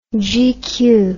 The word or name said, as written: gq